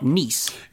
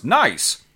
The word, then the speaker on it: Nice: I mean, it's not Nice. – It's pronounced Nice.